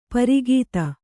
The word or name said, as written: ಪ್ರಘಾತ